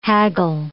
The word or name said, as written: haggle